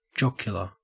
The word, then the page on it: jocular: Australian English